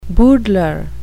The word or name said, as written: boodler